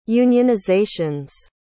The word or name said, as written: unionizations